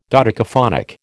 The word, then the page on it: dodecaphonic: American English